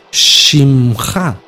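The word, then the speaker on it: Shimcha (שמך): Entonces Shimcha, nombre tuyo, Shimcha. Son tres letras, Shin, Mem y Haf, Sofit. Shimcha, Shimcha. Así se pronuncia, nombre tuyo, nombre tuyo.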